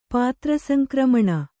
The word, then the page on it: ಪಾತ್ರಸಂಕ್ರಮಣ: pātra saŋkramaṇa